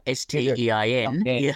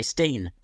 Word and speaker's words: Stein: I mean, when you think of a Stein of beer, S T E I N, you don't say Steen. no, no. Um, but uh, yeah, it, but names can often get mispronounced or mangled or whatever. Spelling of names is an interesting one too. But in this case, right, it is like beer Stein.